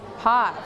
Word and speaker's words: Path: Don't forget to pronounce th sound. เสียงเธอเธอเธอเราต้องเอาลิ้นไว้แตะไว้ที่หลังฟันอะไรคะฟันล่างหรือฟันบนฟันบนนะคะ Path. ตรงนี้ Path.